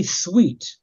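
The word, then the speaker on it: Thweatt: Today's guest on Real Conversations is Charlie Sweet.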